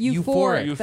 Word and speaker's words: euphoric: You're, like, in a fork state. – A fork state? – Euphoric. That's what I meant.